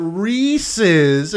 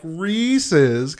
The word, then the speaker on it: Reese's: This is what proves that Reese's is pronounced like Reese's because it rhymes with pieces.